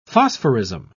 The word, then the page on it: phosphorism: British English